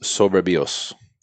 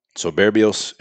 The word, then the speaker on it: Soberbios: It's pronounced Soberbios. Soberbios.